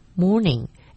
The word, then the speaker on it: mourning: Morning, M-O-U-R-N-I-N-G, 애도. 이 아침이란 의미의 morning, M-O-R-N-I-N-G와 같은 발음이지만 스펠링이 틀립니다. 애도의 morning은 M-O-U-R-N-I-N-G.